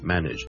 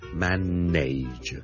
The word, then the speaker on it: manage: 小叔今天想跟大家講 manage 這個字的發音，manage M-A-N-A-G-E manage。由於這個字後面 N-A-G-E 呢，nage 呢是串做 N-A-G-E，很多人就會將它讀成 manage 嘅。咁但係事實上呢，我呢個字呢係讀 manage knit knit knit，你當係好似 N-I-D-G-E 咁讀。